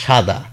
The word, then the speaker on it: সাদা: দন্ত স এর উচ্চারণ বানানে লিখি, যেমন দেখুন আমি বললাম সাদা, দন্ত স একার দয়াকার, কিন্তু আমি সাদা বলছি না, আমি বলছি সাদা। তাহলে আমি বলছি কোনটা, আমি যেটা বলছি সেটা কিন্তু তালব্য স, এস এইচ।